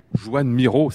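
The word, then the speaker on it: Joan Miró: Joanne Miro, c'est bien comme ça qu'il faut prononcer, m'avez-vous dit euh, juste euh, à l'instant.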